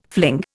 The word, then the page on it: fling: Indian